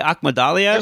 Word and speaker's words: Akhmadaliev: And, Akmal, how do you say his last name again? – I would say Akhmadaliev.